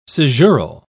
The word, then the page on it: caesural: British English